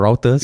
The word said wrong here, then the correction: Reuters: You said Routers, I think.